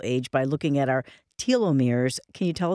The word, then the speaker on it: telomeres: Let's turn to telomeres. Is that how I pronounce it? – That's a good way to pronounce it.